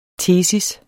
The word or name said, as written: tesis